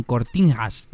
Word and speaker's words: Cortiñas: Eh, Uy, lo tiene con cortiñas. Cortín, ¿cómo se pronuncia? Eh, bueno, NH es como rollo portugués, se pronuncia así, ¿no? Cortiñas. Sí.